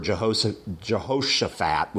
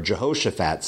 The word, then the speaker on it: Jehoshaphat: And so he asks for Jehoshaphat, would be perhaps a better way to pronounce that, for his help going up against Ben-Hadad.